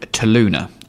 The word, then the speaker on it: Toluna: it's a company and service called Toluna, um, which is spelled T O L U N A, um, which I always spell wrong, but that's how it's spelled.